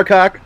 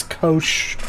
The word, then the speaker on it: COC: It's a soft C at the end. It's kosh.